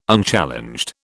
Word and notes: Unchallenged: British English

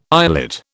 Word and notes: islet: British English

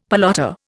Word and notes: pelota: Indian

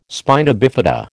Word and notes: spina bifida: American English

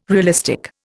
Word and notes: realistic: Indian

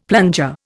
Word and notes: plunger: Indian

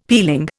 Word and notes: peeling: Indian

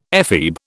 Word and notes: ephebe: British English

